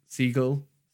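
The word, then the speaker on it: Segel: Siegel? – Yeah, no, yeah, yeah, yeah, yeah.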